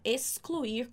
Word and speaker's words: excluir: Só um detalhe para essa pronúncia que você não vai falar excluir com som de S. Aqui a gente está falando ex, como na palavra táxi. Então, excluir, ok?